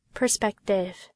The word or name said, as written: perspective